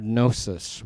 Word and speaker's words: gnosis: The most common word for knowledge in the New Testament is the word gnosis with a silent G.